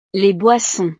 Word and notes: les boissons: frances

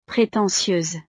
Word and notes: pretentieuse: frances

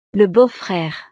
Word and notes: le beau frere: frances

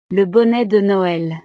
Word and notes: le bonnet de noel: frances

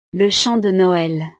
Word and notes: le chant de noel: frances

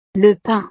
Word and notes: le pain: frances

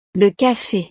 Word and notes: le cafe: frances

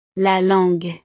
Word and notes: la langue: French

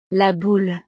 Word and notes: la boule: frances

